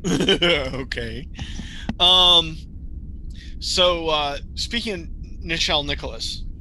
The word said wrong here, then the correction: Nichols: Nichols is her name. – Nichols, I'm sorry.